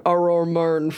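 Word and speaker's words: Arror Murn: Again, for those of you who are tuning in, the town's name is spelled A-R-R-O-R space M-U-R-N, which looked cooler spelled out than I realized it sounded when I said it aloud.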